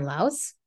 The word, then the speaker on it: Laos: And I have a podcast called Ignite Your Confidence with Karen Laus.